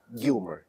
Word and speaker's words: Gilmar: So we are here at Codebeam America 2022, and I finally met in person Carlo Gilmar. Is that the right pronunciation, Gilmar? – I think that in Spanish is Gilmar. – Gilmar.